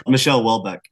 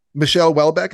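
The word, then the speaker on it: Michel Houellebecq: Michelle Welbeck, yeah. – Michelle Welbeck, is that how you say it? – Yeah.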